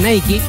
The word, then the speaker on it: Nike: La FEBA Chile anunció este jueves que llegó a un acuerdo con Nike, porque se pronuncia Nike, para su renovación como sponsor técnico de las selecciones nacionales.